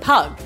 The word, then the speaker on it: pygg: You say it pug, but it's spelt P-Y-G-G.